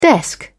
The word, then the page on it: desk: British English